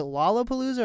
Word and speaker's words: Lollapalooza: Is it Lollapalooza or Lollapalooza? – You got it. First time, yeah. – Okay. – Lollapalooza.